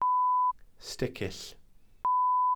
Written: sticill

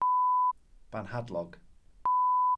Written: banhadlog

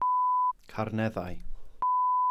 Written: carneddau

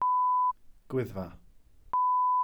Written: gwyddfa

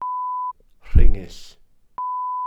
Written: rhingyll

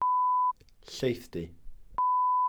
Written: llaethdy

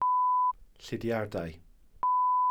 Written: llidiardau

